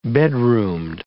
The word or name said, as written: bedroomed